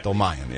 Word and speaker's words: Dolmayan: Dolmayan, yeah, I always screw it up.